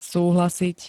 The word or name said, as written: súhlasiť